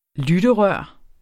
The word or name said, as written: lytterør